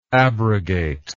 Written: abrogator

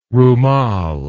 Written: rumal

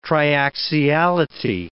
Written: triaxiality

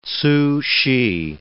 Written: Tz'u-hsi

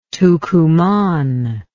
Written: tucuman

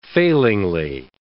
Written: failingly

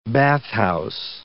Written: bathhouse

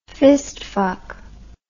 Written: fistfucker

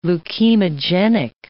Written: leukemogenic

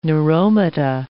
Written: neuromata

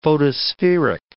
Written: photospheric

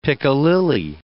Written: piccalilli

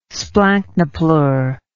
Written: splanchnopleure

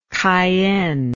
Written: cayenned

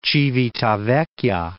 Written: Civitavecchia

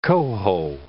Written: coho

